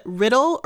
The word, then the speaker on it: Riddell: is I might mispronounce it Charlotte Riddle or Riddell. I've actually um, I've seen some places where they are quite emphatic about it being riddle.